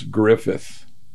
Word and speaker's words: Griffith: I have a name that's hard to, I've always thought was hard to pronounce, Griffith, G-R-I-F-F-I-T-H. My dad, when he was introducing himself to somebody, he would always say, Fred Griffith, like Andy.